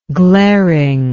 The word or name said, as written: glaringness